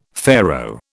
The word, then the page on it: Pharaoh: British English